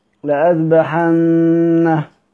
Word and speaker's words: لأذبحن: كلمة لأذبحن في ألف بين الهمزة والذال رسما لكنها لا تنطق، عشان لو واحد ممكن ما واحد ما عندوش قواعد الرسم يجي يقرأ يقول لأذبحن لأذبح، لأن ينطق الألف رسما لكن في الحقيقة إنها إيه لا تنطق ولكنها ثابتة في الرسم.